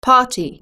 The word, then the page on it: party: British English